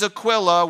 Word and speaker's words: Aquila: Husband's name's Aquila, wife's name's Priscilla. It rhymes.